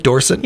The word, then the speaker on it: Dorset: In 2025, UK Dog Surfing Championship in Dorset, England. Am I saying that right? Dorset? – Yeah, that's correct.